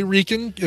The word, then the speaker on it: Reichen: I would say Reichen. If it's German, you always pronounce the second of the two vowels. – Sure. – In the IE.